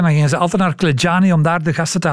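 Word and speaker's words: Clejani: Eh, Klejani wordt het uitgesproken.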